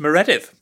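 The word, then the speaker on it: Meredydd: My name is pronounced Mereded and I'm very pleased to be here.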